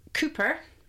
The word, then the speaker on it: Cupar: And then, of course, you've got Cooper, which is actually spelt Cowper, kind of C-U-P-A-R, Cowper, but it's actually Cooper.